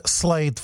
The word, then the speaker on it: Slade: Jak se teda píše Slade foneticky? Slade, krade nebo dlabe? – No, slade. – Slade, přesně tak. Hele, a je to i podobný, viď? Slade a čte se to Slade.